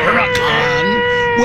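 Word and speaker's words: Huracán: I bring it up because a dude in story number one went to one of those places, and he got the Lamborghini Huracan. – Sounds right. – Pronouncing that correctly.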